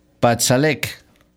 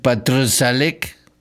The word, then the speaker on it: Patrzalek: miren, se llama, el guitarrista se llama Marcin Patsalek, pero con una R antes que ya no puedo pronunciar. Patsalek, pues sí, si no tendría que decir Patrzalek.